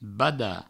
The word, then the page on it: badar: bada; Provençal